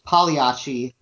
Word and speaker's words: Pagliacci: So I think usually in America, at least many idiomatically, it's often pronounced Pagliacci with the G kind of elided, which brings it a lot closer to 2 syllables, which enabled Smokey Robinson to sing, "Just like Pagliacci did, I'll try to keep my head in Tears of a Clown."